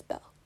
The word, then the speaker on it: Belle: Then Belle, like the Disney princess Belle.